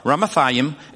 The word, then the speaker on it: Ramathaim: I'm going to try and use the, the Hebrew pronunciation because these are so hard. Ramathaim,